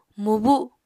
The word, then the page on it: mubu: Apatani